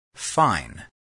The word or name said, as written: fine